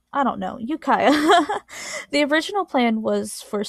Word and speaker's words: Ukiah: Ukiah. Yukiha. Sounds like you. Oh, wait. Yuki, I definitely typed that in wrong. Ukiah. Okay, so I was right. Ukiah.